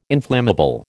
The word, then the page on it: inflammable: American English